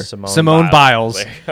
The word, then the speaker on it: Simone Biles: Simone Biles.